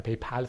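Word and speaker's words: PayPal: پیپال فکر کنم تلفظ درست تریه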